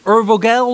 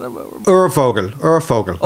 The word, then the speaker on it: Urvogel: Now, you speak a bit of German, so how do you pronounce the word U-R-V-O-G-E-L? Is it Urvogel or something like that? – Urvogel. – Like that. – Urvogel. – So the V is pronounced, probably to any Germans listening, but the V like that is pronounced slightly as an F, and Urvogel, which would mean original bird or prototype bird.